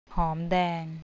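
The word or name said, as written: หอมแดง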